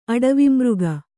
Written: ಅಡವಿಮೃಗ

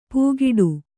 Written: ಪೂಗಿಡು

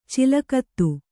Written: ಚಲಗತಿ

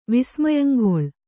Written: ವಿಸ್ಮಯಂಗೊಳ್

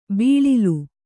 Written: ಬೀಳಿಲು

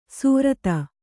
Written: ಸ್ವರಿತ

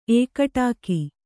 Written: ಏಕಟಾಕಿ

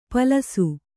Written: ಪಾಲಿಸಿ

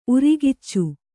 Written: ಉರಿಗಿಚ್ಚು

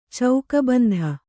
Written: ಚೌಕಬಂಧ

